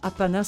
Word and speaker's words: Appanah: Apana s'écrit A-P-P-A-N-A-H.